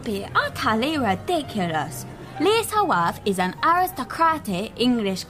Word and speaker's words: Liese: Her name is Lisa. Pronounce Lisa and spell L-I-E-S-E.